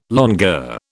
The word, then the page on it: longueur: British English